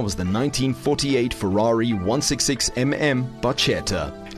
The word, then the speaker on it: barchetta: Something I learned about the song is the word barchetta, actually pronounced barchetta, is an Italian word meaning small boat.